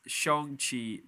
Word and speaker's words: Shang-Chi: Shang-Chi, the legend of the Ten Rings.